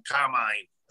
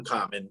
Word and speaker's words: Carmine: My stock answer is, if I'm in a Godfather mood, I'm Carmine. Other than that, I'm Carmine. So that's basically how I pronounce my name.